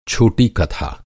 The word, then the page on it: कथा: کتھا